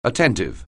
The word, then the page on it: attentive: anglais